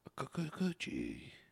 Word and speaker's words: Kikuchi: Himiku Okutuchi. – Kikuchi. – Nice. – It's a T or a K? – It's a K. – Kikuchi.